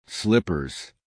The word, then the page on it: slippers: American English